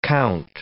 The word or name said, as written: compt